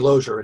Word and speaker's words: Lozier: Am I pronouncing Lozier correctly? – It's actually Lozier.